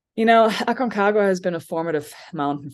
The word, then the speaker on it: Aconcagua: Aconcagua. say it one more time. – Aconcagua.